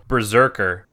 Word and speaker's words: BRZRKR: It's called Berserker. – Ah, okay. – Yeah, it's spelled in all capitalized letters, B-R-Z-R-K-R.